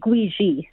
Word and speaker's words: Gwizhii: Did I say that correctly? Gwisley? – It's actually Gwiji. – Gwiji. Okay, I'm sorry. – It's okay. It's spelled G-W-I-Z-H-I-I.